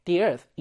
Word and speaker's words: the earth: 읽을 때는 얘가 모음 발음을 시작하기 때문에 the earth 이렇게 읽어줘야 되고요.